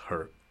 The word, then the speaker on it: He: That's actually how she pronounces He. It's Her.